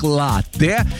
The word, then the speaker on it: латте: И знаете, это правильно, потому что слово итальянское, ударение там стоит на первый слог, латте. И вот, несмотря на обилие гласных, произносится твердо, без мягкости, латте.